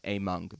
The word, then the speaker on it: Amung: He can't go watch VODs and check out Amung and go, they finally, like, all seem to have agreed that his name is pronounced Amung, by the way.